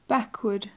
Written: backward

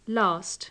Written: last

